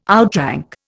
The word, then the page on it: outrank: Indian